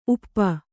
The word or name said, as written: ಊಪು